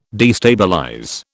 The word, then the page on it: destabilize: British English